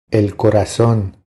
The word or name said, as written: El corazon